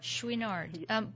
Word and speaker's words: Chouinard: Tell me, other than Yvonne, and is it pronounced Chouinard? Is that how his last name is pronounced? – Chouinard.